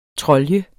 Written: TROLJER

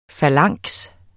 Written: falanks

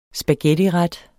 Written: spaghettiret

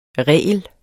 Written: regel

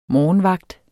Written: morgenvagt

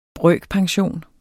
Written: brøkpension

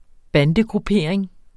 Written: bandegruppering

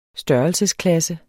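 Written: størrelsesklasse

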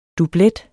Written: dublet

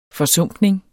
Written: forsumpning

